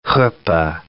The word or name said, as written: huppah